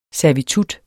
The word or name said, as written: servitutter